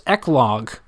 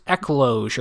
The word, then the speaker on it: eclogue: but it's eclogue, which is spelled E-C-L-O-G-U-E. So it sounds like I'm reading it like there's a pause, like eclogue, but it's just ecloge or eclogue.